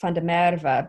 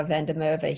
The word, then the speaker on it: Van der Merwe: Um, the last name is correctly pronounced Van der Merwe, but no one's going to get that right. So we just kind of go by Van der Merwe here.